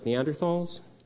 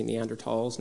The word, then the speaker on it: Neanderthals: We're talking about a population that if we go farther back in time, eventually we would share common ancestors with species like Neanderthals, or I should say Neanderthals now, that's the proper way to pronounce it now, and then species like the Denisovans, Homo erectus, apes in general, chimpanzees, gorillas, and so on.